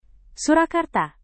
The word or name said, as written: surakarta